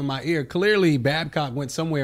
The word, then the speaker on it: Babcock: I think what happened was, however, Babcock, how you say his name? – Babcock. – Babcock took it is what made him get, you know, lose his job.